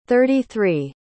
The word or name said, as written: thirty-three